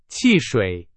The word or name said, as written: 汽水